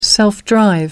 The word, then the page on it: self-drive: American English